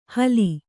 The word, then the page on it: ಹಲಿ: Kannada